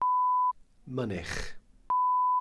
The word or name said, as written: mynych